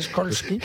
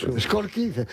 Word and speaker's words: Skolsky: No, señor, no es SH, es SKOLSKY. – Impronunciable. – A la señora Raskolnik. ¿Cómo se pronuncia? Porque es polaco. ¿Cómo se pronunciaría en polaco Skolsky? – Skolsky. Como tú lo has dicho.